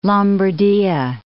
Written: Lombardia

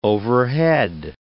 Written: overhead

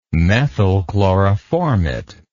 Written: methyl chloroformate

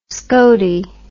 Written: skotey

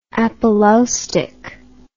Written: apolaustic

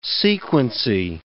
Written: sequency

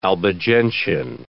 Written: Albigensian

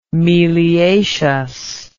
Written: meliaceous

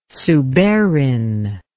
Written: suberin

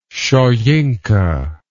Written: soyinka

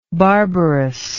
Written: barbarousness

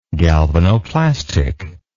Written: galvanoplastically

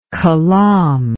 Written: kalam

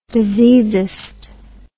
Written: diseasist

